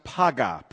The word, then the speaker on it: paga: It's a word that is pronounced paga, P-A-G-A in the Hebrew.